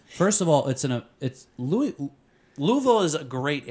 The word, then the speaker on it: Louisville: Louisville. – No, you said it wrong. It's Louisville.